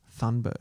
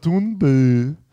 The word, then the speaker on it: Thunberg: So, it's this hard balance I find with Greta Thunberg. – Thunberg. – It's like pronounced like Thunberg, but I can't pronounce Norwegian.